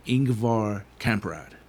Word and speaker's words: Ingvar Kamprad: An extremely unique individual, Ingvar Kamprad.